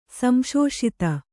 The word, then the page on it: ಸಂಶೋಷಿತ: Kannada